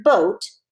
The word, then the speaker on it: boat: So even though you only hear three sounds in the word boat, B-O-T, that's three sounds, there's an A in there that's super quiet and doesn't say anything at all. B-O-T. The A is silent.